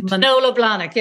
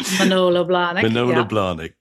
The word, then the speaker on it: Manolo Blahnik: Is it Manolo Blahnik? Is that right? – Manolo Blahnik, yes.